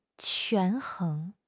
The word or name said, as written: 权衡